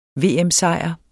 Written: VM-sejr

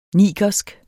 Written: nigersk

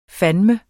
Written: fandeme

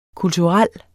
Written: kulturel

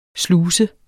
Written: sluse

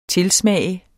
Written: TILSMAGT

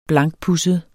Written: blankpudset

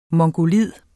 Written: mongoloid

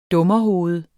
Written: dummerhoved

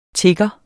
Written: tigger

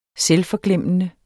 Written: SELVFORGLEMMELSE

